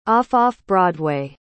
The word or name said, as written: off-off-broadway